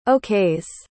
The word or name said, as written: okays